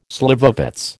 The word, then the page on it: slivovitz: American English